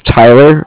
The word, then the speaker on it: Tyler: Okay, so my name is Tyler, T-Y-L-E-R, and I'm calling with Cardiff. It's spelled C-A-R-D-I-F-F, as in Frank Frank.